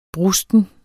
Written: brusten

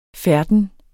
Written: færden

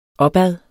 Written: opad

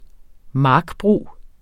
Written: markbrug

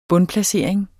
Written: bundplacering

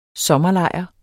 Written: SOMMERLEJR

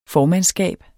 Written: formandskab

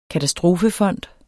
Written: katastrofefond

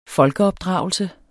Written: folkeopdragelse

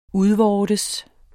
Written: udvortes

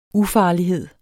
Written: ufarlighed

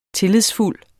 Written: tillidsfuld

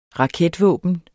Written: raketvåben

